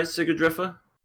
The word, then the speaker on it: Sigrdrifa: Let's talk about Warlords of Sigurdrifa. I don't know how to pronounce this damn show's name. – You could. That sounds right, Sigurdrifa.